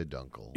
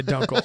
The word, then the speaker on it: Dunkel: Dunkel? How would you say that word? – I would say dunkel. – Yeah, dunkel.